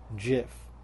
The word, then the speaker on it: GIF: According to the creators, CompuServe, it's pronounced GIF, like with a J.